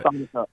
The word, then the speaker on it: Sonico: I hope that I'm pronouncing that right. Sonico. – Yeah, Sonico.